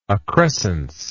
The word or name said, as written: accrescence